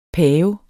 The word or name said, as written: pave